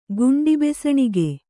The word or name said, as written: ಗುಂಡಿಬೆಸಣಿಗೆ